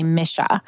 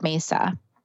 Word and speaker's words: mesa: It's also, the pronunciation is actually misha, but the Western people, we call it mesa.